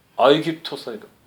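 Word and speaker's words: 아이귀토스 (Αἴγυπτος): 원래는 G 발음이 규 발음이 나는 거죠. 그래서 아이귀토스인데요. 콥트는요 아랍 사람들이 헬라화된 그곳에 와서 이 나라 뭐야 이름이 그랬더니 아이귀토스니까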